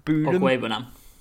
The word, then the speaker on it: Okwuegbunam: And another one, just to quickly mention, and I'm going to absolutely butcher this name. I apologise. But it's Albert Okwuegbunam. – Okwuegbunam. – Thank you.